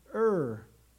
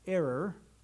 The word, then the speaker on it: Ur: So verse 3, she conceived and bore a son, and he called his name Ur. I don't think it should be pronounced error, but that's what it comes to be, sadly.